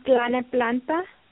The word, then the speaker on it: Tlalnepantla: It's T-L-A-L-N-E-P-A-N-T-L-A. – Wow. Now, how do you pronounce it? Do you even know? – Yeah, Planet Planta.